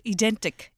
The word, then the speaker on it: Ydentik: It's called Ydentik. – Ydentik.